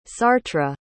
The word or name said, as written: sartre